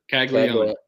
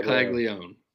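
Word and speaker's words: Caglianone: Caglione. Yep, Jacques Caglione. – Yep. – That's his name. – Caglione? – Yes, Jacques Caglione.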